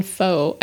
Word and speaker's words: pho: How do you say that? – Something different than pho, I think. – Yeah, I think you're right. I think it's like pho. – Pho. – Yeah. – Pho. It's spelled P-H-O.